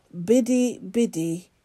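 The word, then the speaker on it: Bidi Bidi: So Nathan's thought for the day is to look up pictures on the internet, if you can, of Biddy Biddy in Uganda. And Biddy is spelt B-I-D-I, and it's twice. Biddy Biddy, B-I-D-I, in Uganda. The world's largest refugee camp.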